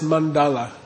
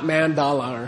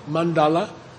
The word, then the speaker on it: mandala: Okay, then that's how to pronounce mandala. It's not mandala or something. Okay, mandala, or Pandita, okay, Hatha Yoga. These are all retroflex, okay.